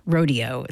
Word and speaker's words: Rodeo: Ending with the Hoedown, those were the four dance episodes from the ballet Rodeo by Aaron Copland, or Rodeo, it's sometimes pronounced, Buckaroo Holiday, Chorale Nocturne, Saturday Night Waltz, and Hoedown with the Cincinnati Pops Orchestra and conductor Eric Kunzel.